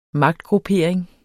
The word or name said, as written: magtgruppering